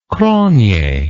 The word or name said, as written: cronje